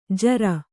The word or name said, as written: ಝೂರಿ